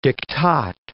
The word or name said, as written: diktat